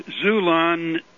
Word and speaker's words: Xulon: Well, Zulon is a so-called Christian publisher and printer. – How do you spell that? – X. It's the same X in Xerox or xylophone. – Okay. – X-U-L-O-N. – Very good. X-U-L-O-N.